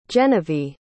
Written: genevie

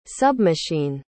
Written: submachine

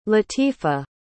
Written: latifa

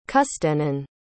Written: custennin

